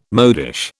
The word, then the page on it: modish: British English